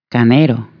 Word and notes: montone: Italian

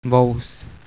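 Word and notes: vous: Czech

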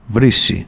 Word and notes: aixeta: Catalan

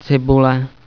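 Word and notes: ajuin: Flemish

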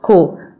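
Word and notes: толгой: Mongolian

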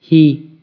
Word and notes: piena: Italian

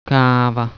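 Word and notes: قهوه: Persian

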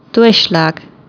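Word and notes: afgieter: Flemish

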